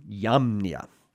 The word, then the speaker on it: Jamnia: Now, south on the coast or near the coast, a couple of days walk, there is a city named Yamnia. Jamnia is the way it looks like it's spelled in English for us.